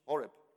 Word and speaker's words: Horeb: Horeb.